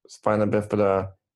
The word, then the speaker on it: Spina Bifida: but you grew up with Spina Bifida and Hydrocephalus? – Perfect, yep.